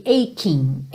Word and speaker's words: aching: My aching heart, esse CHI vai ter um som correspondente ao QU pra gente aqui, ok? Aching, aching.